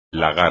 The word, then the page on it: lagar: espagnol